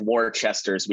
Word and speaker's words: Worcester: First of all, Tim, how'd I do pronouncing Worcester? – I think you did a lot better than even some of the locals here.